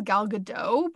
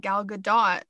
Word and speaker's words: Gadot: So at the start of the pandemic in March, Gal Gadot, I always thought her name was Gal Gadot, but apparently you pronounce it Gal Gadot, which is interesting.